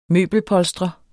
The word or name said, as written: møbelpolstrer